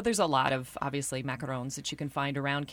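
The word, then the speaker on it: macaron: And you are also recommending, depending on your pronunciation, French macaroons or French macarons? – Macarons. Yes.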